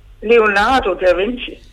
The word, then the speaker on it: Leonardo da Vinci: Jeg prøver ham at lave Leonardo da Vinci. – Ja, ved du hvad, det var godt udtalt, det der.